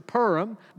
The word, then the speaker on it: Purim: I think that would be the most common, like P-O-O-R, Purim, Purim. Some would say Purim, like a cat purrs, Purim. That's probably technically the correct way to say it.